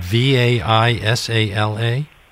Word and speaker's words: Vaisala: What kind of work do you do at Vaisala, which is spelled V A I S A L A? – Yes, it's Vaisala.